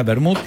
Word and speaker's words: Wermut: A base de vino y absenta, acuérdate, absenta o ajenjo es el origen de la palabra vermut, que justamente significa ajenjo, esta palabra alemana que yo no sé cómo se pronuncia, pero se escribe wermut. – Vermut. – Ok, ahí está, bien, ahí, con acento en la W.